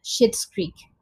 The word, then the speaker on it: Schitt's Creek: So the show that I want to talk about is Schitt's Creek. I know it sounds like shit, but it has, well, it's spelled S-C-H-I-T-T.